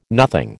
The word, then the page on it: nothing: American English